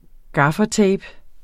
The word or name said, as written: gaffatape